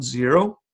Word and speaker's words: Xero: Our favorite cloud bookkeeping software is called Xero. It's spelled X-E-R-O.